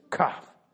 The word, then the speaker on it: Kaf: Now, this is how you say it. You put your elbow up and you say, Kaf. There you go. See, now you know how to cough in public politely and say a Hebrew letter.